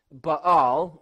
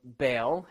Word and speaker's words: Baal: Sidon was this pagan nation that worshipped a variety of gods, but primarily of which was Baal, or Baal, however you want to pronounce it, and also Asherah.